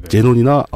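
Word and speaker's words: xenon: 그렇게 전기를 만든 다음에 예전에는 이제 이걸 크세논이라고 읽었었는데 요즘엔 다 제논이라고 읽죠. 어. XE. – 네. 제논이나 아르곤 같은 원소를 고온의 플라즈마 형태로 바꿉니다.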